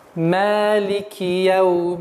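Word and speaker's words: Малики яумиддин (مَالِكِ يَوْمِ الدِّينِ): Ма — мягкий звук. Ма — нет, ошибочное произношение. Ма. Ма. И ударение в размере двух огласовок. Малики. Так как мы читаем с вами по чтению Хафс от Асма по пути Аш-Шатабия, удлинение является обязательным. Малики в нашем чтении не читается.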